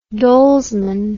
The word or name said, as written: dolesman